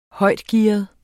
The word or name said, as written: højtgearet